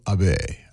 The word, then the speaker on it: Abene: The arranger, Michael Abene. I hope I'm saying that right. A-B-E-N-E. Abene? Yeah. Okay. We began with Kei Akagi.